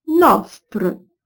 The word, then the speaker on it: nostre: Mais j'ai voulu prononcer, ligne 19, nostre, ligne 23, fostre, ligne 28, chose, ligne 36, close, ligne 56, toast. Et tous ces mots se prononcent aujourd'hui avec un O fermé, du moins en français standard.